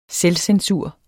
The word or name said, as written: SELVCENSUR